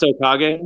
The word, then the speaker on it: Okage: It's pronounced Okage? – Okage, yeah, correct.